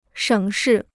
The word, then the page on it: 省事: shěng shì